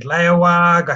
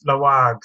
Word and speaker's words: Laoag: I said Lawag, I said all sorts of names and eventually I was put in my place and told it's Laoag.